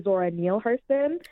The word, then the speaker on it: Zora Neale Hurston: I'd love to tell you a little bit more, but before we jump too into it, a common mispronunciation is Zora. It's actually Zora Neale Hurston. – Ah. – It is common because she has the E at the end, but it's Zora Neale Hurston.